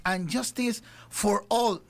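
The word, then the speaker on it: ...And Justice for All: Pero un día como hoy, señoras y señores, de 1988, Metallica oficialmente lanzaba este álbum que tremendas canciones, And Justice for All. No sé si lo dije bien, mi querida Raquel. – Sí, ¿no? Estamos bien.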